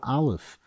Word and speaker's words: Aleph: The letter Aleph is always usually refers to keser, because the way we pronounce it, aleph, it's the same letters as the word pele.